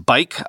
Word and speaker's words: Buyk: That's pronounced Bike, I've learned, B-U-Y-K.